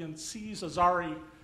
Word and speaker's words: Aziz Ansari: You know, there'll be the political equivalent of the Aziz Ansari, that's how you pronounce his name, date gone bad, compared to Harvey Weinstein.